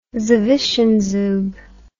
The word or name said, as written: zwischenzug